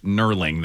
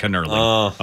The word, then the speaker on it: knurling: 800 people have let me know it's pronounced knurling. The K is silent. Sorry. – Oh. – It's not knurling.